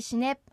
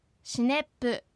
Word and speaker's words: シネプ: エコッチャタ、イタンキ、シネプアンナ。 – エコッチャタ、イタンキ、シネプアンナ。 – エコッチャタ、イタンキ、シネプアンナ。 – うまく発音できましたか？シネプをシネップのように母音をつけて発音しないように注意してください。